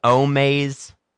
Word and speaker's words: Omaze: Do you know about Omaze? Omaze? – No, but it rhymes with my nasal spray Flonase.